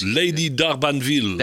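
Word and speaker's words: Lady D'Arbanville: En Cat Stevens met Lady Bardanville. Zeg ik dat goed? – Lady Darbanville. – Darbanville, juist, ja.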